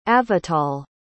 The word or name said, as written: avital